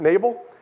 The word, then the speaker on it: Nabal: I'm going to pronounce it that way. I know you can pronounce that word different ways, but Nabal.